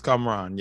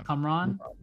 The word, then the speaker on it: Kamran: What do you guys think of the new heartthrob, Cameron? Is it how do you pronounce it? Kamran? – I think it's Kamran. Yeah. – Kamran. Okay.